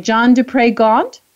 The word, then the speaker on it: John du Pre Gauntt: And so we're going to hear from the fabulous John, tell me if I'm saying this right, John du Pre Gauntt, is that right? – Perfect.